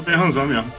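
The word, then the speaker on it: Menzoberranzan: Não, você fazer uma invertida em Maison Berranzan, por exemplo, né? não sei se estou falando certo o nome, mas eu falo desse é jeito. É isso né? aí, Maison Berranzan mesmo.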